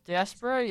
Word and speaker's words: diaspora: How do you say that word? I think it's diaspora. Yeah, I think so. – Yeah, I think you did great.